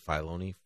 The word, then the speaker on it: Filoni: Philoni. Okay, Philoni. Dave Filoni, Filoni.